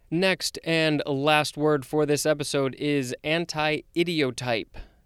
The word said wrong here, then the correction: anti-idiotype: or actually anti-idiotype. There is a hyphen. It is spelled A-N-T-I hyphen I-D-I-O-T-Y-P-E.